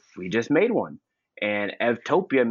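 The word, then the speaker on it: eutopia: So we just put the EU on topia, and EU in Greek is pronounced, the V is pronounced as a V. So instead of U, it's Ev. And so Evtopia is a good place.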